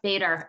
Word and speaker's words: Bader: Yes, Bader.